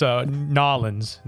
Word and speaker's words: New Orleans: The Haunting of Delphine Laloui, because it's Nolens. That's the proper way to pronounce that. All right, place. – So we're jumping the map. We're going to Nolens. – Nolens, Louisiana. – New Orleans. – No, it's Nolens.